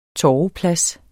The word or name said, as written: torveplads